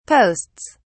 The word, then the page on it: posts: British English